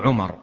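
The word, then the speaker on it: عمرو: والحكمة في كتابتها التمييز بين عمر وعمر، فهذه الواو تميز بين من اسمه عمر ومن اسمه عمر، ولولا هذه الواو لم يحصل التمييز. فالواو إنما تكتب للتمييز بين عمر وعمر، بين عمر وعمر، فهي واو تكتب ولا تنطق.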